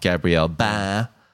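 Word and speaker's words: Gabriel Bá: I think it's Space Boy is his name, but anyway, it's just got a very quirky vibe and very stylized art from Gabriel Ba. – Mm. – That's how you pronounce his name.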